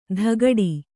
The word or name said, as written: ದಗಡಿ